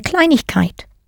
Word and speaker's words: Kleinigkeit: Eine Kleinigkeit. Note the letters IG pronounced ich that sneaked in there between klein and keit.